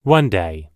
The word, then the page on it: one day: American English